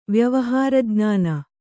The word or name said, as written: ವ್ಯವಹಾರಜ್ಞಾನ